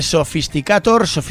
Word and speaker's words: Sophisticator: Así que vamos a escuchar un tema de Sophisticator, sería más o menos pronunciado en inglés,